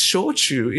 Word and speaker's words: shochu: I told you it's shochu. It's not soju. Soju is a Korean pronunciation of the word, same with Chinese character. Shochu is a Japanese pronunciation.